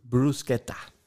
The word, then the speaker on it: Bruschetta: Bruschetta. Richtig. Also Betonung liegt auf dem Brus und ja. dann KE, ta, Bruschetta. Okay?